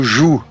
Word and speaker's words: Jo: Mas alguns pronunciam também como Ju, Ju Nesbo, né?